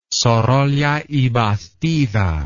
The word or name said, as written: sorolla y bastida